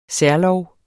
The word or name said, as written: særlov